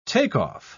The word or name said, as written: take-off